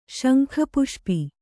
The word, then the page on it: ಶಂಖಪುಷ್ಪಿ: Kannada